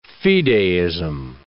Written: fideism

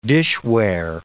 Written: dishware

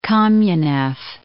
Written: Kamenev

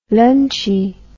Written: lunchie

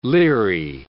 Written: leery

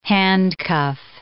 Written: handcuff

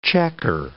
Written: chequer

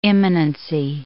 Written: imminency

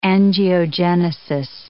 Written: angiogenesis

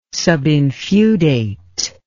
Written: subinfeudate

